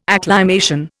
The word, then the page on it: acclimation: Indian